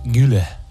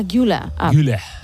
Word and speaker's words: Güler: Creo que Arda es Arda Güler. – Ah, Güler. Y como he dicho, Güler.